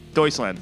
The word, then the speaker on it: Deutschland: Deutschland. – Deutschland. – Thank you. – Yep, like doily, but without the doyle.